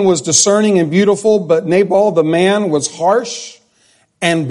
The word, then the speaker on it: Nabal: Now the name of the man was or I guess I've always pronounced it Nabal, but I found out this week it's Nabal.